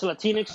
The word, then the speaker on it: Latinx: Latinx, did I get that right? Yeah, it's Latinx Studies and Religion, the title.